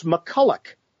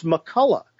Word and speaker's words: MacCulloch: He said, you've been mispronouncing our name the whole year. I said, are you serious? What is it? He says, it's MacCulloch.